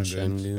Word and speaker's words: Şengün: I think his name is pronounced, maybe you guys know, Şengün. – Oh, that guy, Alperen Şengün.